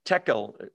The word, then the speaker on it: tekel: The next thing, tekel. Dude is not in the text. I inserted that. I apologize. Tekel, T-E-K-E-L, rhymes with a Hebrew word with the same root, which is shekel.